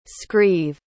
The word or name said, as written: scrieve